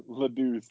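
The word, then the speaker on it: La Douce: Stop calling it LaDuce. – I don't know how to pronounce it. – LaDuce. – Is it Deuce? – Yeah, it's LaDuce. LaDuce is French.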